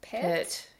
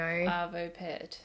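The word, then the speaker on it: Arvo Pärt: I think it's Pert. – Yes. – I don't know. – Arvo Pert.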